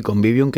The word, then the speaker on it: convivium: Los romanos, a ver si lo digo bien, convivium. – Sí, convivium, que de hecho hay muchas palabras que hoy utilizamos, vienen precisamente de ahí.